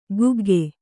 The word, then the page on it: ಕುಗ್ಗೆ: kugge